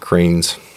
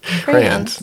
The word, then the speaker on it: crayons: And we've been relegated back to the basement and just learned. – Oh. Cranes. – You said they're crayons. – Crayons. – Yeah, that's right. You got it right this crayons.